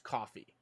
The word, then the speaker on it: Ko-fi: I personally use, is it Ko-fi or coffee or both? Whoever, it doesn't matter. – It's supposed to be pronounced coffee.